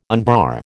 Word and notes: unbar: American English

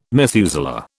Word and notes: Methuselah: British English

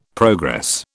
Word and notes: progress: British English

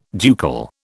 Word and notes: ducal: British English

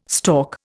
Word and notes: stork: Indian

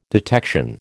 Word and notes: detection: American English

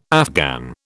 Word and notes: Afghan: British English

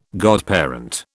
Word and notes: godparent: British English

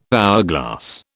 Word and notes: hourglass: British English

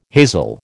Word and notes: hazel: American English

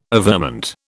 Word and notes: averment: British English

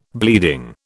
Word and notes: bleeding: British English

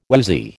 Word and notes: woozy: American English

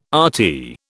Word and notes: RT: British English

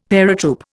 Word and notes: paratroop: Indian

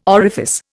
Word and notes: orifice: Indian

